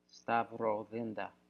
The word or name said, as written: stavrothenda